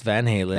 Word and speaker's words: Van Halen: Eddie and Alex Van Halen.